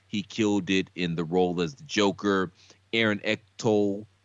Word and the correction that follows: Eckhart: I think that's Eckhart. how you say his name. Eckhart.